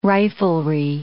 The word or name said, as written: riflery